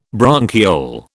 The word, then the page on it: bronchiole: British English